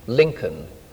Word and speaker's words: Lincoln: I was born in a cathedral town on the eastern side of England, Lincoln, spelt with the silent L.